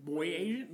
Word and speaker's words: Boyajian: Boyajian? Lady boys? – No, it's Boyajian.